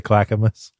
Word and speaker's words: Clackamas: Alexa, how do you say Clackamas? – I pronounce that Clackamas, but I'm always working on how I say things, and I might not have it right.